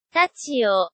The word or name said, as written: tachio